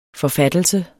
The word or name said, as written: forfattelse